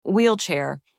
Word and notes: wheelchair: anglais

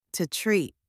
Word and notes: to treat: anglais

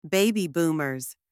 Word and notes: Baby Boomers: anglais

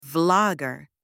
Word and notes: vlogger: anglais

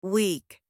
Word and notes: weak: anglais

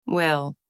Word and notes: well: anglais

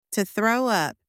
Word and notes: to throw up: anglais

